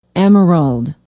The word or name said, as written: emerald